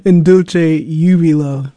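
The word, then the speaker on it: In Dulci Jubilo: Well, a particular one, In Dulce de Jubilo. Well, let me make sure I got all of that right. Oh, gosh, the pronunciations. In Dulce Jubilo. There we go.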